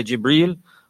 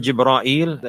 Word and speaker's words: Jibreel: You can say Jibreel or Jibra'il, and there are many other ways to pronounce this word likewise.